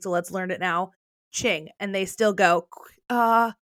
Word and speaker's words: Qing: I think before I would have pronounced it King, because I know Q doesn't make like the Q sound, so I probably would have said King, but it's Qing.